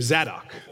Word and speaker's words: Zadok: The Sadducees, this group that's mentioned here, these are people who have descended from the line of a priest named Zadok. It's spelled Z-A-D-O-K, Zadok. This is a priest that was at the time of David.